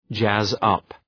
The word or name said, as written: jazz up